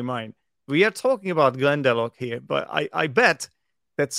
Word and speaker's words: Glendalough: because obviously that's the thing about Irish names and name places. It is bloody difficult spelling, and it's pronounced in a completely different way than it is spelled. So that is true. So Glendalough is Glendalough, G L E N D A L O U G H.